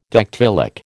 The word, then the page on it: dactylic: American English